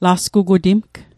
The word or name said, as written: lasgugutimg